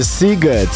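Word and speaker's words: Sziget: Bo to jest bardzo ważna rzecz, bo pisze się Siget, a wymawia się Siget.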